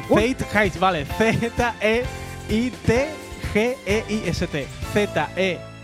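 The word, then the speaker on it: Zeitgeist: Se llama, atención, Faith Haste. Así es como se lee.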